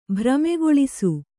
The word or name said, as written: ಭ್ರಮೆಗೊಳಿಸು